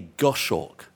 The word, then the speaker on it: goshawk: MacDonald's account of training a goshawk. Is that how you say it? A goshawk.